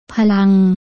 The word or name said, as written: พลัง